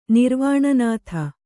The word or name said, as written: ನಿರ್ವಾಣನಾಥ